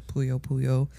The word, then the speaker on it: Puyo Puyo: In other news, we also have Puyo Puyo. I said that right? – Yeah. – Puyo Puyo.